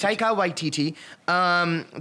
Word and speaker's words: Taika Waititi: I can never get his name right. Is it Taika? – Yeah, Taika Waititi. Okay, it's Taika Waititi.